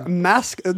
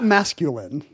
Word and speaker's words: Maskelyne: So Jasper Maskelyne, not masculine, Jasper Maskelyne.